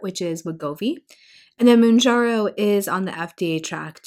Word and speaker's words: Mounjaro: and then Mounjaro. Did I pronounce that correctly? – Yep.